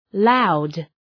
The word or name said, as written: loud